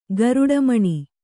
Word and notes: ಗರುಡಿಮನೆ: garuḍi mane

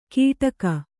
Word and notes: ಗಿಡಗ: giḍaga

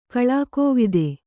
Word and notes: ಕಳಾಕೋವಿದೆ: kaḷākōvide

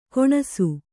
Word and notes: ಗೊಣಸು: kannada

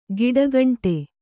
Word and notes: ಗಿಡಗಂಟೆ: giḍagaṇṭe; Kannada

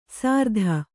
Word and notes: ಸಾರ್ಧ: sārdha

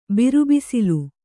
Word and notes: ಬಿರುಬಿಸಿಲು: biru bisilu

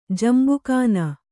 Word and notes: ಜಂಬುಖಾನ: Kannada

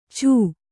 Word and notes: ಛೂ: chū

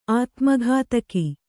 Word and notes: ಅತಿಮುಕ್ತಕ: ati muktaka

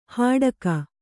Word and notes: ಹಡಗು: haḍagu

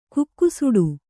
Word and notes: ಕುಕ್ಕುಸುಡು: kukkusuḍu; dial.

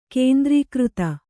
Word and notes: ಕೇಂದ್ರೀಕೃತ: kēndrīkřta

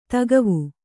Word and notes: ದಿಖಾವು: Kannada

